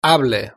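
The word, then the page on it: hable: Spanish (Spain)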